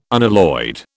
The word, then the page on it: unalloyed: British English